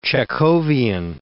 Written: Chekhovian

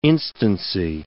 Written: instancy

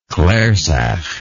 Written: clarsach

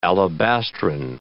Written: alabastrine

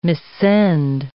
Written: missend

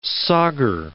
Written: sauger

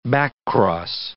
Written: backcross

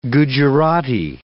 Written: Gujerati

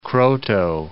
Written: Kroto

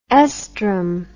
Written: oestrum

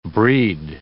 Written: breed

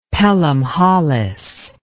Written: Pelham Holles

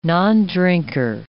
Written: nondrinker

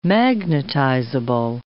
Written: magnetizable

